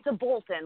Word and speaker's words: Aboltin: It's Aboltin. Like I always tell people, like, I'll be Aboltin out of here when I'm done with this conversation.